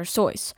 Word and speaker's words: Seuss: It's actually Dr. Seuss. Rhymes with voice.